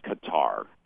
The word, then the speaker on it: Qatar: So basically the pronunciation that I've stuck with is Qatar.